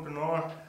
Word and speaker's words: Pinar: How do you pronounce your last name, Jordan? – That's close enough. It's Jordan Pinar. – Pinar. – Jordan Pinar. – You got to roll the R a little bit.